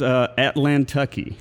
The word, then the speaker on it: Atlantucky: So your brewing company is called Atlantucky. – Correct. You said it right.